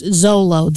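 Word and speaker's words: Xolo: Yeah, but they, apparently it's pronounced Zolo. The Zolo dog, a little dog, I don't know.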